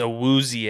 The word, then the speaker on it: Awuzie: And then Owazi, I always butcher his name. – I think it's Awuzie. – It is Awuzie. Gosh, I'm so sorry, Awuzie.